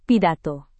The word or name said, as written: pidato